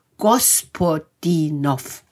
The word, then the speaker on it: Gospodinov: Ich wiederhole nochmal den Namen des Autors, denn ich habe ihn mit Betonung ausgesprochen. Er heißt Gospodinov.